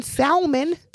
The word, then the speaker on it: salmon: People pronounce the L in salmon. – I hate those people. Whoever people is, if y'all are listeners of mine, niggas. Dear niggas, Mr. Nigga, Mrs. Nigga, stop saying the L in salmon. It's silent for a reason.